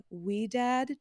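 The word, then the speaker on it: Ouidad: And so I'm using her curl cream and I've used her gel, but I also like the Wee Dad gel. I think that's how you say it. It's O-U-I-D-A-D.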